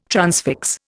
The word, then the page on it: transfix: Indian